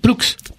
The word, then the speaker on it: Poulx: Poulx. – Poulx. P-O-U-L-X.